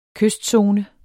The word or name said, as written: kystzone